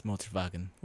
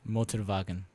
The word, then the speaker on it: Motorwagen: It's Motorwagen. – Well, scheiße. – Motorwagen is... – Motorwagen. You pronounce the W's as V's.